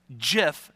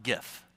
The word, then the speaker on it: GIF: "It's pronounced JIF, not GIF."